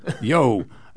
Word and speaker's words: Yeo: I'm going to call him Yeo, Y-E-O. Would you know how to pronounce that? – Yeo.